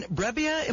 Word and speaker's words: Brebbia: Once again, the guest list includes Jose Martinez, our first baseman, John Brebbia, am I saying that right? – That's right.